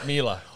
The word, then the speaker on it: Mila: Is it Miller or Miller? It's Mila. Oh, Mila. – No, it's Mila.